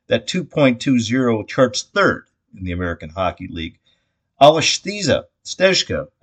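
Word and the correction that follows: Aleš Stezka: I knew I was going to butcher that. Let's try it again. Alash Stezka, also Czech goalie, by the way, 3-2 in net for the Birds this season.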